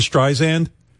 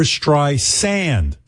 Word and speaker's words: Streisand: You know how people say Barbra Streisand? Well... it's Barbra Streisand, sand, like the sand on the beach. – Okay. – So anyway, everyone says Streisand, and quite frankly, I don't.